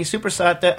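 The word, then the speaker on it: soppressata: But how do you pronounce it? How's the actual Italian thing? – Supersata. – Supersata. Oh, supersata.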